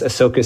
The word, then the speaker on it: Ahsoka: Some of the world building is still there, but especially with this Ahsoka series, and yes, you are pronouncing it correctly.